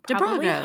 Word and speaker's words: Dobrogosz: That's probably Dabrogaz. Hungarian. – Dobrogos. – So, Hannah, thank you for your work. – In Hungarian, the SZ is just pronounced S.